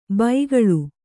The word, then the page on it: ಬೈಗಳು: baigaḷu